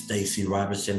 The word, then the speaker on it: Stacy Roberson: And my name is Stacy Roberson, and that's spelled S-T-A-C-Y Roberson, R-O-B-E-R-S-O-N.